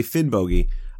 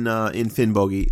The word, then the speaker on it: Finnbogi: Now, I know when I say Finnbogi, I'm putting too much emphasis on the O in the second syllable, but I would say that the hard G that I'm using in Finnbogi is much closer to the Old Norse than the soft Y that you find in the modern Icelandic.